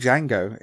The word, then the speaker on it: Django: Like the name Django, it's got a D and a J at the start, even though it's a silent D.